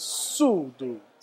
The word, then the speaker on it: Sudo: So it's speed and tempo in Chinese, and the correct pronunciation is Sudo.